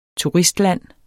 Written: turistland

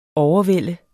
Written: overvælde

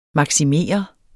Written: MAKSIMEREDES

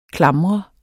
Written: klamre

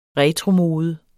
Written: RETROMODE

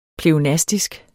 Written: pleonastisk

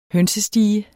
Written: hønsestige

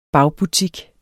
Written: bagbutik

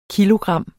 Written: kilogram

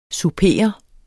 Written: souper

